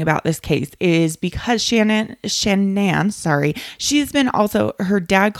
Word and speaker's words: Shanann: Her name has been said all different types of way, but her name, according to most, is Shanann, is how to pronounce it.